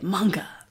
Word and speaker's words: manga: Apparently, that's how you say it. Not manga, you say it manga.